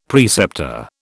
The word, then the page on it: preceptor: British English